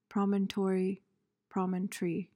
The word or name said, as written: promontory